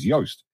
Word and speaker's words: Joost: She's Dutch, as is Joost, and, and that's why Joost, rather Joost, he, to pronounce his name properly is Joost.